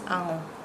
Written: ango

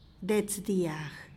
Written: dets dyag

